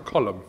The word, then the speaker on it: Colm: But if it was Colm, wouldn't it be C-O-L-U-M-N? Like Colm. – Like a Colm? – Yeah. – Like a Rowan Colm. – Yeah, isn't his name C-O-L-M? – His name is pronounced Colm. – Yeah, but is it C-O-L-M? – Yes.